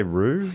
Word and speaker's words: rue: Is that at least say Rue? – Yeah, yeah.